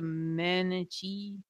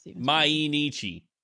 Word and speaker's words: Mainichi: Mainichi.